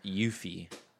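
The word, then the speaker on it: Aoife: It's Yuffie.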